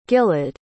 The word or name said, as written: gilad